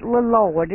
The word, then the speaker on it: Lloyd: Leloy Daney were third. – You don't have to pronounce the second L in Lloyd. – It ain't Lloyd. – It ain't Lloyd. It's just Lloyd.